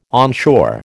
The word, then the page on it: onshore: American English